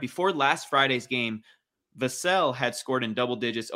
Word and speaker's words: Vassell: Devin Vassell. I sure hope I'm saying his name right. – It's Vassell. It's like Cassell. – Thank you. Okay. Devin Vassell, like Sam Cassell.